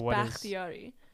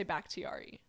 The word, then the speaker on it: Bakhtiari: In Farsi, because it's Bakhtiari, so it's Bakhtiari. Mm-hmm. But nobody, like, English speakers can't do the kh, so it's I just say Bakhtiari.